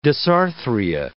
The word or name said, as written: dysarthria